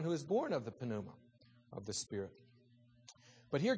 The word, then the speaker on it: pneuma: Pneuma is how you say that word. Pneuma.